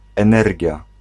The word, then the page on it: énergie: France; [e.nɛʁ.ʒi]